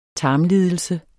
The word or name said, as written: tarmlidelse